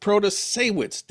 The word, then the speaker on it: Protasiewicz: We all had to learn how to pronounce the name of the woman who won, Janet Protasiewicz.